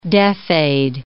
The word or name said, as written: deaf-aid